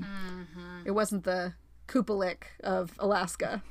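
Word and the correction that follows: Qalupalik: Did I say that right? – No. – Oh. Yes, I did. – Kalupalik. – Oh, Kalupalik. Oops.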